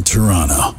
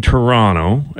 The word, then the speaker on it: Toronto: See, there was a good one in there, man from Toronto. And then Kevin Hart's really going heavy on the second T.